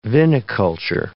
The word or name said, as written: viniculture